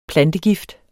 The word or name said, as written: plantegift